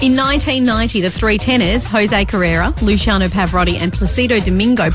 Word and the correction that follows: Carreras: I think the S in Carreras is pronounced. Jose Carreras, Luciano Pavarotti and Placido Domingo.